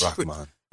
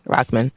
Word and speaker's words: Rahman: And is it too soon against Hussein Rahman? Did I say it right? That was too much? – Rahman. – Yeah, you know, I'm putting too much emphasis. Rahman.